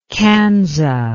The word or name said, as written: kansa